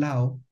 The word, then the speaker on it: Laos: Y ellos, para que hubiera un sonido similar al Lao en francés, tuvieron que añadir una S final. Pero esa S final no se pronuncia. Sin embargo, cuando la leemos en español o en inglés, queremos leer Laos, y de ahí viene esa confusión.